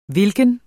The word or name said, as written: hvilken